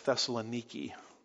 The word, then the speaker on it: Thessaloniki: And when I traveled there, I figured out that I had mispronounced it all these years. They call it something like Thessaloniki. And so that's obviously kind of discouraging when you've been preaching the Bible and you keep calling it Thessalonica. But there in Greece, they call it Thessaloniki.